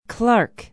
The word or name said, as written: Clark